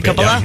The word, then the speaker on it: Coppola: The Godfather director, Francis Ford Coppola, is that how you pronounce it, Coppola? Yeah.